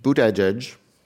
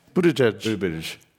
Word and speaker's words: Buttigieg: earlier today, let me pronounce this correctly, Pete Buttigieg. – Buttigieg.